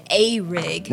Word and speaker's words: Arig: Is a rig or a rig? I haven't really decided how I want to pronounce it. – It's spelled A-R-I-G. – A-R-I-G.